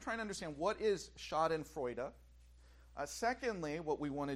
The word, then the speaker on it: Schadenfreude: This word, and for a long time I pronounced it as Schadenfreude. Apparently that was not the proper pronunciation. It's Schadenfreude. As I said, it's a German word, Schadenfreude.